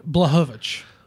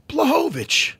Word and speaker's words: Błachowicz: How do you pronounce it again? – It's Jan Błachowicz. – Błachowicz. – Yeah, the CH.